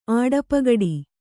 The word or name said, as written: ಆಡಪಗಡಿ